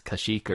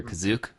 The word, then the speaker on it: Kashyyyk: Kashyyyk or Kazook, right? Are those the two main ones? – Yeah, the only place they say Kazook is on the holiday special, but since it was first, that's canon.